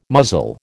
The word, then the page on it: muzzle: American English